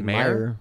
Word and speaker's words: Mayr: But if you do want to try Rebel Wilson's diet, her diet method, it's called, it's M-A-Y-R. How do you pronounce that? M-A-Y-R. How do you pronounce that? – Sounds like Meyer.